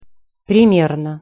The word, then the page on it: primerno: Russian